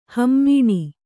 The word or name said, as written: ಹಿಮಾನಿ